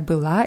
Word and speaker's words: была: В субботу вечером она опять была на дискотеке Ja, bei billa ist die Betonung auf a.